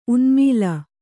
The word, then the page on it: ಉನ್ಮಾಳ: unmāḷa